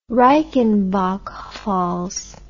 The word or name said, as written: reichenbach falls